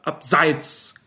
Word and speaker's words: abseits: Abseits Kamke oder abseits Kamke? Es heißt natürlich abseits mit Betonung auf der ersten Silbe,